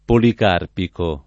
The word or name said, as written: policarpico